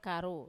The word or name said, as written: karu